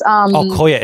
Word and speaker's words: Kuya: So Kuya means Kuya. It's Kuya.